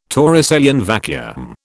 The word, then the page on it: Torricellian vacuum: British English